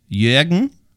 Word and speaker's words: Jürgen: So the Ö, the Jürgen.